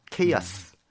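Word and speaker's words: chaos: 케이아스. – 케이아스. – 케이아스라는 그 단어도 굉장히 멋있게 발음을 해요. 케이아스 이러면서 카, 오스죠 우리가.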